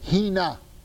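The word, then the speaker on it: Hina (ἵνα): Actually, it's a three-letter word, but it's pronounced in English as four letters. Hina.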